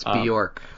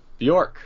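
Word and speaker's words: Björk: It's Bjork. – Bjork. – Bjork.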